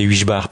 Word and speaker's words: Heugebart: Il y a son frère Camille Passery à la trompette et Florian Heugebart, euh, prononcé Huichbar par les francophones,